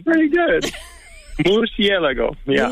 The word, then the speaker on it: murciélago: It is pronounced murciélago. – Oh, thank you. – Can All you right. do it? Murciélago? – Murciélago.